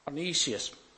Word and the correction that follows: Onesimus: I don't know if that was pronounced right. Every time I try and pronounce this, I get a different pronunciation. Onesimus, that's it.